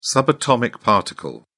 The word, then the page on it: subatomic particle: British English